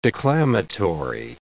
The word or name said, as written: declamatory